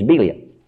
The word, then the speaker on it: Belial: Or we call it Belial. Actually, the correct pronunciation is Belial.